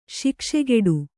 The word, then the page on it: ಶಾಕಶಾಕಟ: Kannada